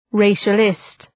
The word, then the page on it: racialist: ‘reıʃə,lıst